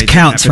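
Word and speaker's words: Tkautz: Is that how you say her name? – Melissa Couch, yeah.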